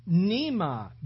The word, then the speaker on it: pneuma: The word Spirit there that is spoken over 20 times in this passage is the word, the Greek word, pneuma, which is spelled P-N-E-U-M-A. But it's pronounced pneuma, which literally means to breathe.